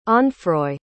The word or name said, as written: onfroi